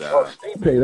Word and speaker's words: Stipe: Yeah, he's Stipe.